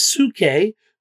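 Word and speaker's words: suke (ψυχή): Well, in the Greek, soul, the word is suke. We uh, we transliterate that into English and we pronounce it psyche, the mind, the emotions, the will of a man that is his soul.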